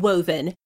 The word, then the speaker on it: Wohven: It's called Woven, but it is spelled W-O-H-V-E-N.